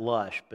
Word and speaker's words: Laish: And Ishbosheth sent and took her from her husband, Peltiel, the son of Laish. Actually, you can pronounce that Lush, but that just sounds weird.